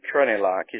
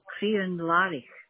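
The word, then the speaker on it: Crianlarich: And is it Crenellach? Is that correct? – No. Crenellach. – That's the one. I do apologise. My pronunciation was a little bit off there.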